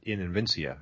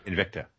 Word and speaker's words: Invicta: But she's fighting for the 125 pound belt in Invincia. I think that's how you say it. – Invicta.